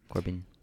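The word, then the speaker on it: Corbijn: Corbin.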